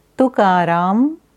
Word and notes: Tukaram: Sanskrit

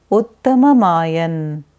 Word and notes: Uttamayana: Sanskrit

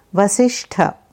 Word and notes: Vasistha: Sanskrit

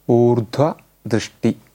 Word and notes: Urdhvadrishti: Sanskrit

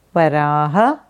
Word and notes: Varaha: Sanskrit

